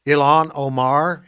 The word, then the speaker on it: Ilhan Omar: Ilhan Omar, am I pronouncing her name correctly? – I believe that is correct.